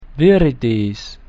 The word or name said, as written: viridis